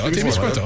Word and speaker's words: Témiscouata: La Nodia, la Beauce, cette île, Abitibi-Témiscamingue, la Tuque, Matane, Témiscouata, Saint-Jean-sur-Richelieu, Saint-Hyacinthe, Drummondville, Rimouski. Il y en a un que j'ai probablement mal prononcé. – Non, t'es autant dit. – C'est-tu Témiscouata, c'est ça? – Témiscouata, c'est bon.